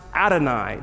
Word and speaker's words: Adonai: When you read the Bible, however, it's pronounced Adonai. It doesn't look anything like YHWH.